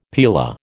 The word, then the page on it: paella: American English